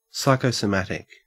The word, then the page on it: psychosomatic: Australian English